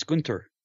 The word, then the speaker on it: Gunther: People have to say it properly. It's Gunther.